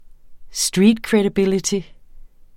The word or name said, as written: streetcredibility